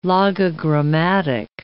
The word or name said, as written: logogrammatic